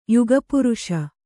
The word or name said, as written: ಯುಗಪುರುಷ